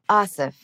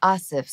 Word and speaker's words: Aasif: Asif. – Yeah. – Asif. So it is the softer like Anna.